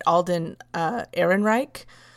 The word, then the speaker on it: Alden Ehrenreich: Alden Ehrenreich.